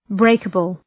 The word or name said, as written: breakable